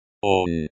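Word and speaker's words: on (он): Here is a simple example of a sentence using ot, ot, onushe ot doma, onushe ot doma, on, he, pronoun, nominative case. This is pronounced like the English word on, but with a slightly softer n sound.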